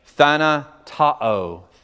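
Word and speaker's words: thanatao (θανατόω): The word is, if you're taking notes, thanatao. Thanatao, T-H-A-N-A, ta-o, T-O, long O, thanatao.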